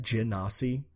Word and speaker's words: genasi: Is that the proper pronunciation of that? Genasi? I think it is, because it's like genie, right? – Yeah.